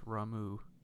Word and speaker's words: Ramuh: It's pronounced Ramuh.